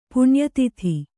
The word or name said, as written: ಪುಣ್ಯತಿಥಿ